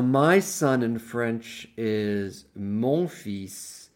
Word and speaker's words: fils: A common mistake is for people to want to pronounce the L because it's spelled F-I-L-S. So you cannot say mon fil. That would be wrong. You have to say mon fils.